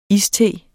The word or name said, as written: iste